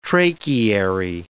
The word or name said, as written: tracheary